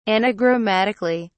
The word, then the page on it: anagrammatically: British English